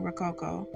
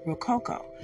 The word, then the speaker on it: Rococo: She was doing Rococo, but that's how she was pronouncing it in the movie. But in actuality, it's Rococo.